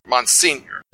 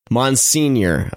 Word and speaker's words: Monsignor: Monsignor. – Monsignor. Okay. – Got it. – There we go. It's not that hard there. – Right. – So Monsignor.